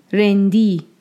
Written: Rendi

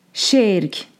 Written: Šerk

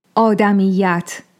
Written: Ādamiyyat

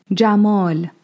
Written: Jamāl

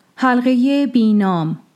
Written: Bīnām

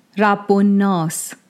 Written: Rabb-o-Nās

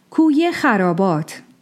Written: Kūy-e-Xarābāt

